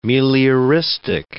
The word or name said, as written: melioristic